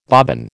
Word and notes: bobbin: American English

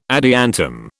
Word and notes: adiantum: British English

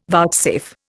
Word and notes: vouchsafe: Indian

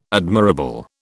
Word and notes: admirable: British English

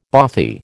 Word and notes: bothy: American English

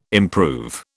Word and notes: improve: British English

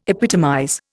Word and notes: epitomize: Indian

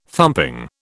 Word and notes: thumping: British English

